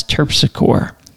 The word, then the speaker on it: Terpsichore: We have Chapatulas and Terpsichore, which here we would pronounce Terpsichore.